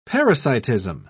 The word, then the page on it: parasitism: British English